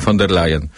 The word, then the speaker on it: von der Leyen: Ale chciałbym też przypomnieć, jak pani Ursula von der Leyen, chyba nie umiem wymawiać tego. – Nie, właśnie pan umie. Wielu nie umie, mówiąc von der Leyen, a pan dobrze mówi, von der Leyen.